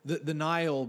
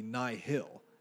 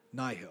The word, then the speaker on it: Nihil: The Nile, because we keep saying it so often, it was like, I really hate this. I really hate this. Because to me, it'll always be Nihil. Nihil.